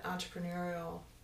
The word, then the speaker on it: entrepreneurial: And that's that entrepreneurial. Did I say that right? – Yeah, you did.